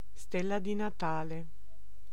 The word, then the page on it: stella di Natale: /ˈstɛl.la di na.'ta.le/